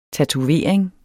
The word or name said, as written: tatovering